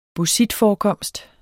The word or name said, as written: bauxitforekomst